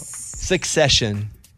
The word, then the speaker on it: Succession: Secession. – Yeah. – Am I not saying it right? – I don't think so. – Succession.